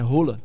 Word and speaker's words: Haaland: Passaram a vida toda chamando o Haaland pelo nome errado. – É? – É. Sabia como é que é a pronúncia do nome dele? – Sim. – É Rola.